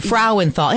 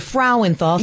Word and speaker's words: Frauenthal: Did I say that right? – Frauenthal. – Frauenthal. And I've been to Germany, the Frauenthal Theater. – Yes, there you go. You nailed it.